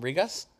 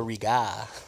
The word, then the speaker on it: Riga: From Rigas? I feel like you should pronounce the S. This is the Riga. – No, it is. It's Riga. – Excuse me? – Riga. – R-I-G-A.